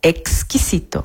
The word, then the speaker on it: exquisito: Presta atenção na pronúncia, exquisito. Mas cuidado, se você disser que a comida está esquisita, esquisita com S, o garçom vai achar que o prato está estranho ou ruim.